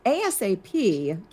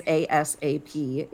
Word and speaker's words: ASAP: Now, ASAP can be, if I say it as ASAP, it's an initialism. But if you say ASAP, then, you know, magic. It's an acronym now.